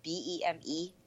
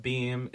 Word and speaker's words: Beme: It's B E M E. I didn't. – Yeah, B E M E. Wait, that's Beme. – It's, it's, no, no, it's called. It's pronounced Beam.